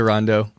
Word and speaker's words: Rondeau: Yeah, there's always been dispute about how my last name is supposed to be pronounced. So I go with, I say Rondeau.